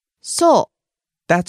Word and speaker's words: そう: そう。 – That's right. Or, is that so? – そう。そう。 – Falls on the second syllable う。